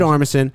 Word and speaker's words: Armisen: Fred Armisen. Am I saying it right? – Uh-huh. – Fred Armisen.